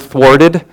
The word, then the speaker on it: thwarted: The, the W is not silent in that one like it is in sword, but thwarted. You have to make sure you pronounce the W when you say that one.